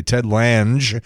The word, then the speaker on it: Ted Lange: Ted Lange, and that is the correct pronunciation of the bartender from The Love Boat, is 74.